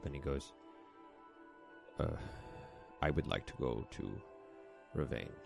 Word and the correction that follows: Revan: Did I say it wrong? Fuck me. – You mean Revan? Oh, motherfucker. I'd like to go to Revan.